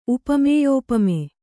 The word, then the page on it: ಉಪಮೇಯೋಪಮೆ: upamēyōpame